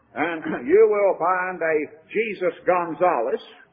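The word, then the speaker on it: Jesús: They pronounce it Jesus, by the way. Jesus, or Jesus, J-E-S-U-S. Here's Jesus Prado, Jesus Gonzalez. That's a very common name among Hispanic people. And again, it's pronounced Jesus, but it's spelled Jesus.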